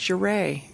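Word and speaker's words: Ouray: How do you pronounce Uray? I said, it's Uray.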